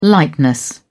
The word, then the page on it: lightness: British English